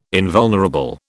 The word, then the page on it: invulnerable: British English